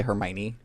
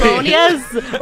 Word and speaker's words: Hermione: That's how a lot of us learn how to say Hermione. – Yes. – Like, you look at that word and you're like, Hermione? – No, exactly. – Hermonious.